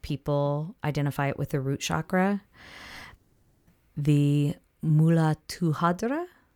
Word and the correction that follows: Muladhara: The chakra? Muladhara? – Muladhara. Hmm.